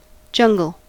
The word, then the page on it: jungle: American English